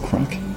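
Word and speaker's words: craic: Which has come on the scene, which is basically the Jefferson Davis show, and that's been rebranded as Kilos of Crack .com. So go and check that out. It's really entertaining, and you can get that under Kilos of Crack. And crack is spelled the Irish way, so it's C-R-A-I-C.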